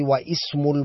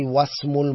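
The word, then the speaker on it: wasmul (واسم): If something is written before the word ismun, then we don't pronounce the hamza. I don't say wa ismul muhandisi, I say wasmul muhandisi.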